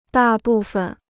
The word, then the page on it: 大部分: dà bù fen